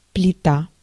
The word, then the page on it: panel: /ˈpæn.əl/